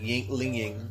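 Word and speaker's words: Yuengling: It's spelled Yangling, like Y-E-U-N-G, but they say it's, they call it like Yangling.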